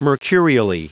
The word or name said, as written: mercurially